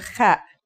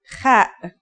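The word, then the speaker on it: Kha (خ): The last letter we learn here is the Kha. This letter, like the Ha, has no English counterpart. Kha is a sound some people make when they laugh.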